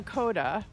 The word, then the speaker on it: Coda: Wilder, before we get going on Coda, which is the way you pronounce the movie we're doing today, which rhymes with Yoda,